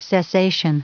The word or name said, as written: cessation